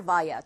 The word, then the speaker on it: via: via or via, 둘다 발음 가능하고요.